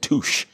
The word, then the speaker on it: Touche: It was Deloitte and Touche. There, I was going to be a CPA. – And yeah, it rhymes with douche. – Yeah, it does. It's douche.